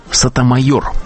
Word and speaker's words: Сотомайор: На этот пост президент Обама выдвинул Соню Сотомайор. Ее фамилия произносится с непривычным для американцев ударением на последнем слоге.